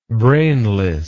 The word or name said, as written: brainlessness